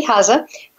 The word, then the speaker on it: Hozza: Candy, is your last name, is it Hooza? Did I say it right? – It's Hozza. – Sorry. Okay, Candy Hozza, who's an incredible medium, as you can hear, folks.